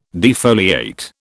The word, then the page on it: defoliate: British English